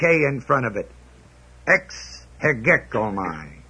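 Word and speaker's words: hegekomai (ἡγέομαι): The word count is the word hegekomai, H-E-G-E-O-M-A-I. Long E, first E is long E.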